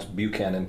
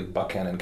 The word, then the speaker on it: Buchanan: I lived in Southwest Virginia for 15 years, and I lived in a place that most people would pronounce Buchanan County, but it's pronounced locally Buchanan County.